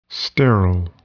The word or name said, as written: sterile